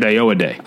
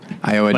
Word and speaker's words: Ayoade: So I think it's pronounced Richard Ayoade. – Ayoade? Ayoade. – Like, I owe you a day.